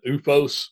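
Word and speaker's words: OOFOS: And I have recently acquired a pair of, and I'm going to mispronounce this, you might have to edit it, UFOS slippers. – Yes, that's right.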